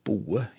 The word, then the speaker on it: båe: Ja, siste leddet er ordet båe, som blir uttalt boe i dette området.